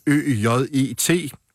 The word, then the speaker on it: øjet: Altså, hvis man tager sådan noget som ØJET, øjet, så kan det udtales øjet, og det er der rigtig mange mennesker, der gør, og der er ikke en kat, der vil kunne høre, at der er så mange bogstaver, som der er.